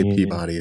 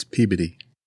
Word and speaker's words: Peabody: Don't say Peabody, it's Peabody. – Peabody, yes, I'm sorry. There you go. You are, that's how it's pronounced, I'm sorry.